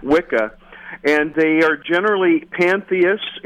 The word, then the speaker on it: Wicca: The word Wicca in modern English, they believe it comes from the Old English term that's pronounced Wicca, which was a reference to a male witch,